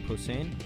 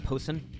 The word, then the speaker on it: Posehn: Back to the show, and joining me here is Brian Posehn. Am I saying that right? – Yep. – It's not Posehn? – No.